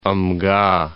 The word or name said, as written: Amga